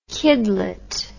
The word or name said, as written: kidlet